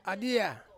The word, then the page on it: adia: Ansus